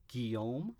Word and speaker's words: Guillaume: I know I'm going to say this name wrong. Robert Guillaume. – I think that's right.